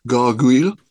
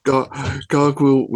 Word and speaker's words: Gargouille: See, I started off strong with the French pronunciation. Now it's all downhill. Gargouille.